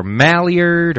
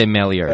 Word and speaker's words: Maillard: Well, because you could say Maillard or Maillard or right. It's the way it's spelled. – I say Maillard. – You say Maillard.